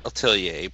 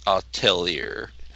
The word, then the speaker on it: Atelier: Some people think it's at Atelier, but it's actually actually I've heard a French person pronounce it. It's Atelier. Interesting.